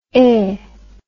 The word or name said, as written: hein